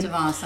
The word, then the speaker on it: DeVos: DeVos, I believe, yes.